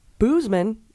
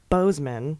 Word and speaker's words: Boozman: Senator Boozman is pronounced Bozeman, and moron is pronounced Moran, though I quite prefer Boozman and moron.